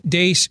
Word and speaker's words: Deace: Like us on Facebook and follow us on Twitter at Steve Dace Show. Last name is spelled D-E-A-C-E.